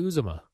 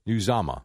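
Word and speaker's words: Uzomah: CJ Uzama. – You're not too far off. – What is it? – Uzama. I think it's Uzama.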